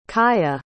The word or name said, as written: caia